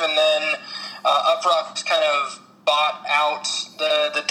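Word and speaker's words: Uproxx: Yeah, UpRocks is.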